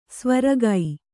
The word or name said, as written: ಸೈವೆರಗು